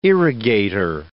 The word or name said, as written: irrigator